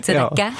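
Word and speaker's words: Tzedaka (צדקה): Och det viktigaste hebreiska ordet, det är tzedaka. – Tzedaka? – Ja. – Tzedaka.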